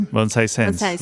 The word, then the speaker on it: Bonsai Sensei: Bonsai Sensei? – Bonsai Sensei. – Bonsai Sensei. – In Spanish, it's Bonsai Sensei. – I was saying it right.